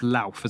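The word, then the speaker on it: Lolth: Loth was known as, and that's L-O-L-T-H. According to this, it's pronounced Louth as though it's spelled with an A, but why the fuck don't spell it with an A then?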